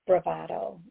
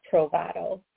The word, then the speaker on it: bravado: I think it's bravado, not provado.